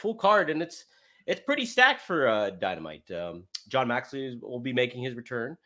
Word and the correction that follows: Moxley: Instead of Moxley.